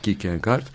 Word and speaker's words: Kierkegaard: Søren Abi Kierkegaard. İnşallah doğru telaffuz etmiştim. Evet. Kierkegaard.